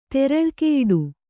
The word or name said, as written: ತೆರಳ್ಕೆಯಿಡು